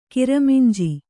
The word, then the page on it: ಕಿರಮಿಂಜಿ: kiraminji